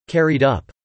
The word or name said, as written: carried up